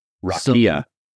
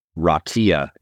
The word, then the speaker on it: Raqia: Rakia. – I'm sorry? – Rakia is the ancient word that the Hebrews used for it. I believe it's spelled R-A-Q-I-A.